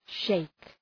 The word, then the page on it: sheik: ʃeık